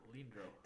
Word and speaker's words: Leandro Barbosa: Leonardo. How do you say that? That's not Leonardo. – It is. – No. – Leandro. – L-E-A-N-D-R-O. – Yeah, Leandro. – Leandro Barbosa.